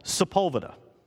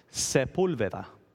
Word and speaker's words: Sepulveda: For instance, there's a place in California, Street, and in English, it's called Sepulveda. Tell me how to spell that from the sounds I just made. Sepulveda. Now I'll pronounce it for you in Spanish, the language of his origin. Sepulveda. Now you hear you hear different vowels there, don't you?